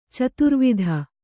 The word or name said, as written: ಚದುರುವಾತು